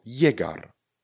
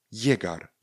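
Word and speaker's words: llegar: Nebo slovo Jegar. Jegar. Dvě na začátku a pak je Egar. Jegar.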